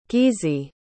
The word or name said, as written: gizi